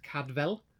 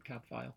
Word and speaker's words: Cadfael: Apparently the author, according to Wikipedia, the author originally intended the name to be pronounced Cadvel, which was aimed at the Welsh, because it's a Welsh name, but in Welsh apparently it would be Cadvael. But the F is pronounced as a V, apparently.